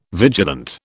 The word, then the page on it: vigilant: British English